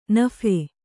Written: ನಫೆ

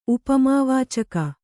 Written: ಉಪಮಾವಾಚಕ

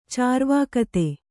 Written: ಚಾರ್ವಾಕತೆ

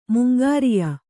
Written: ಮುಂಗಾರಿಯ

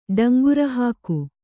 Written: ಡಂಗುರಹಾಕು

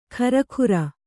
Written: ಖರಖುರ

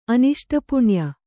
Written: ಅನಿಷ್ಟಪುಣ್ಯ

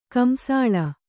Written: ಕಂಸಾಳ

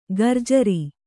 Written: ಘೂರ್ಜರಿ

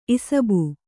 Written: ಇಸುಬು

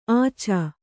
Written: ಆಚ

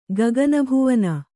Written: ಗಗನಭುವನ

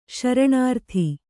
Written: ಶರಣಾರ್ತಿ

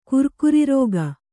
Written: ಕುರ್ಕುರಿರೋಗ

